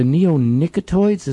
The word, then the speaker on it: neonicotinoids: Especially, what is it, the neonicotinoids? Is that how it's pronounced? – Yes, yeah.